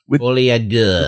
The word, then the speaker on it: Folie à Deux: The first thing on the first day, Warner Brothers presentation put out their first trailer for the Joker sequel, Joker Folie à Deux. I think that's how you say it, right? – Folie à Deux.